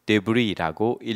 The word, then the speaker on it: Debris: 이것은 프랑스에서 왔기 때문에 맨 마지막에 있는 자음인 S는 읽지 않고 Debris라고 읽습니다. S는 묵음이 되겠습니다.